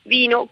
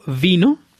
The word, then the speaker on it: vin: Es cierto que la pronunciación es similar. De hecho, Andrea, le he pedido justamente a una francesa que nos pronuncie lentamente las dos palabras. Primero, vino. – Le vin. J'aime le vin. – Ella nos dice el vino, me gusta el vino. Escuchemos ahora pronunciando el número 20. – Vin. J'ai 20 ans. – 20, tengo 20 años, nos dice esta francesa. Vino y 20 son palabras que suenan muy parecido en francés, y como esas hay muchas.